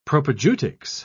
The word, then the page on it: propaedeutics: British English